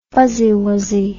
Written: fuzzywuzzy

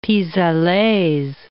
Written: pis allers

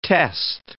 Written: test

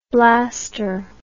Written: blaster

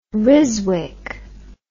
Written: ryswick

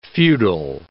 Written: feudal

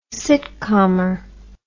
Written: sitkamer

